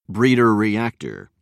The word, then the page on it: breeder reactor: British English